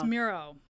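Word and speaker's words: Miroh: And then the other one is the one I was pronouncing as Mirror. – Oh my word. I was like, Mirror? So then when I started playing Mirror, she was like, what's that? Because they do have a song called Mirror. – I didn't remember that song. – It's Miro. – I was like, Miro. Oops, my bad. You know, I can't pronounce words correctly.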